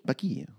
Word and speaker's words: Paqui: Or Packy? How do you say it? Packy. P-A-Q-U-I. – Packy? – Packy.